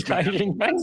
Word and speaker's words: Xi Jinping: Well, Frick, I wonder why they wouldn't be making shirts of this Xi Jinping. So many manufacturers. – Oh, you've said his name correctly.